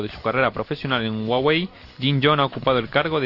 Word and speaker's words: Huawei: Esto lo habíamos mirado cuando se pronunciaba. Y es Huawei.